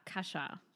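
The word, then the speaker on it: Kasia: Also spricht man das so aus? Ja, Kascha. Kascha Lehnhardt, geschrieben Cassia. Viele sagen, glaube ich, Cassia, genau. aber ich genau. glaube, the original ist Kascha.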